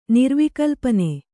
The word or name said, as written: ನಿರ್ವಿಕಲ್ಪನೆ